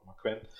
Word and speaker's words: Quinn: Das war Ace Romero, Jungle Boy, Billy Gunn, Glacier und Mark Queen. Äh, Mark Queen oder Mark Quinn, glaubt Mark Quinn, er ausgesprochen.